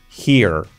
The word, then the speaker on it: here: Escrito jere. pues pronunciación he, con la H que no sea he, he-er, here, here, here, here. Y con la R bien marcada, letra clave de nuestro método, here.